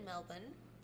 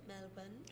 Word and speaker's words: Melbourne: We just did like, we just stayed in Melbourne. That's how they say it, Melbourne.